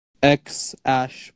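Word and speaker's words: X Æ: And it's spelled X Ash because the AE thingy is Ash called.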